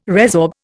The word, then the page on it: resorb: Indian